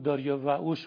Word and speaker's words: داریوش: تلفظ داریوش هم در اون زمان به زبان فارسی باستان تقریبا داریا وعوش بوده